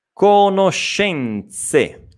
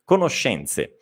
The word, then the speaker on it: conoscenze: Quindi, conoscenze, conoscenze. Nuovamente questa E è sorda, quindi TZ, no? Z, Z, conoscenze.